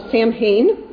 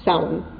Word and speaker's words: Samhain: Samhain. There were a few giggles, and one very kindly adult gently and quietly told me that it was actually pronounced Samhain.